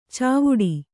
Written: ಚಾವಡಿ

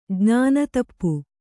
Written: ಜ್ಞಾನತಪ್ಪು

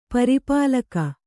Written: ಪರಿಪಾಲಕ

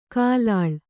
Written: ಕಲ್ಲಾಳ

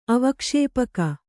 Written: ಅವಕ್ಷೇಪಕ

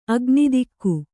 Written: ಅಗ್ನಿದಿಕ್ಕು

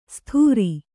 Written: ಸ್ಥೂರಿ

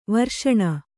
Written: ವರ್ಷಣ

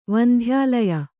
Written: ವಂಧ್ಯಾಲಯ

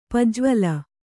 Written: ಪಜ್ವಲ

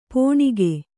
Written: ಪೋಣಿಕೆ

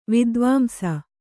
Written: ವಿದ್ವಾಂಸ

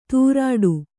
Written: ತೂರಾಡು

